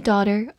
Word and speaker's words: daughter: She's the only daughter of her family. 注意对比一个近义词，医生或者博士叫做 doctor，或者美式发音 doctor。而我们今天学习的女儿，它中间是一个长音 o，并且呢没有 k 这个音 daughter，所以注意一定要区分开来哦。